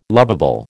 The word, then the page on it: lovable: American English